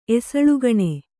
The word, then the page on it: ಎಸಳ್ಗಣೆ: esaḷgaṇe